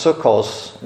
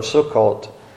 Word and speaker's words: Sukkot: pronounces the T as an S, and so for them it's Sukkos instead of Sukkot.